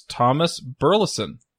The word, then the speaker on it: Burleson: This week, we have a special guest, and that's Thomas Burleson.